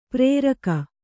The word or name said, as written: ಪ್ರೇರಕ